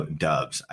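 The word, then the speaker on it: Doubs: Romeo Dubs. I continually call him Dobbs. Romeo Dobbs. So I got all of his names wrong. Usually. it's usually Romeo Dubs.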